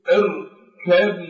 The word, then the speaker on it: اركب: فعندما تقرأ هذه هذا الجزء من الآية المباركة فتقول اركب معنا لا تنطق بحرف الباء.